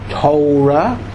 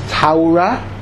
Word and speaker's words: Torah: There is Torah, which is the Yeki pronunciation. There is Teira, which is the Litvisha pronunciation. There is Toira, which is the Polish and Russian pronunciation.